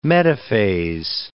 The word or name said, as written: metaphase